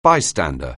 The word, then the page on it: bystander: anglais